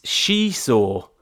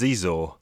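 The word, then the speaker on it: Xizor: I think I never said his name right. It's She-Saw. – Okay, I always used to say Prince Zizor.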